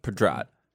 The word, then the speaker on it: Pedrad: Nassim Pedrad, how do you say her name? – Pedrad.